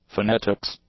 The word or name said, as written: phonetics